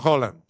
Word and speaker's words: Haaland: El mismísimo actor de Spider-Man, Tom Holland, le mandó un mensaje al vikingo mayor, Erling Haaland, que en noruego se pronuncia Holland también como el de Tom.